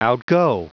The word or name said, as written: outgo